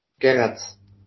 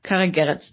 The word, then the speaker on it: Gerets: Was ich ganz interessant fand, die Theorie, unser Trainer, Karel Gerets, ich kann das nicht richtig aussprechen. – Gerets. – Karel Gerets, richtig? Karel Gerets. Das muss ich jetzt abspeichern, hat in einem Interview irgendwie den Vergleich zwischen Boniface und Lassme gezogen.